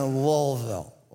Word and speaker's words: Louisville: Louisville. I know that's the right way to say it, but here's the thing. It will be awkward if I try to do it. For me, it's always gonna be Louisville. And